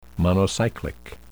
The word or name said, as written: monocyclic